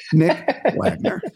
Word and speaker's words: Wagoner: And I asked him the other day, I said, "What is the O silent or not?" He said, "Yes, the O is silent, Nick Wagoner," right?